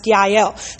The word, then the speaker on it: Jael: It's spelled with a J, but it's actually pronounced Yael.